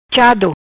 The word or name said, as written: Chado